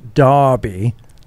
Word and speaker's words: Derby: John Beecham in Derby, he says, pronounced Derby, England.